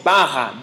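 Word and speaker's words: bara (ברא): bara », B-A-R-A.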